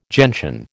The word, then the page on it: gentian: American English